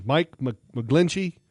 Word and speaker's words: McGlinchey: The left tackle at Notre Dame, Mike McGlinchey.